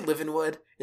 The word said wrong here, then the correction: Livengood: It's Livin' Good. It's spelled Livengood.